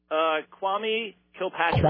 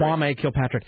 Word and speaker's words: Kwame Kilpatrick: Kwame Kilpatrick. – Kwame Kilpatrick.